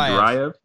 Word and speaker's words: Duraev: Albert, how do you say his last name? – Duraev.